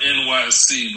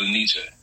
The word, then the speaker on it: Enyce: I never knew how to pronounce that, but it's pronounced NYC, but we was country, so we said Enyce.